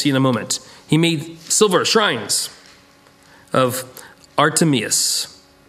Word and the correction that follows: Artemis: I pronounce Artemius? Artemis, thank you, Artemis, brought in a lot of business.